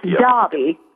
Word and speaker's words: Derby: Well, I appreciate you getting me straight, especially they spell it D-E-R, and it's Derby.